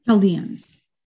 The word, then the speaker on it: Chaldeans: Am I pronouncing that correctly? – Chaldeans. – Say it again? Yes. – Chaldeans? – Chaldeans. – Okay.